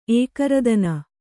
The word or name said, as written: ಏಕರತ್ನಿ